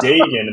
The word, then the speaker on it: Dagan: And then finally it was like, dude, it's Dagan.